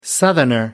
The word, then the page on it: southerner: American English